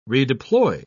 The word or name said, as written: redeploy